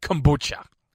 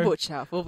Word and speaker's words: kombucha: And I'm talking about kombucha. – Don't do this. – First of all, am I saying that right? Kombucha? – Yes, correct.